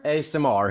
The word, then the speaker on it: ASMR: Nej, men det uttalas på ett annat sätt. Vänta. – Ellen? Ja? Vad heter det här när man pratar nära micken? Så här viskande och äter skit. ASMR heter det.